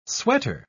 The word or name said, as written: sweater